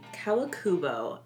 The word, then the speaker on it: Kawakubo: And this year, it was Rei Kawakubo. I hope I'm saying that right. – Yeah.